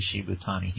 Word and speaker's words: Shibutani: It's like spelled like Shibuya, but it's pronounced, it's read Shibutani.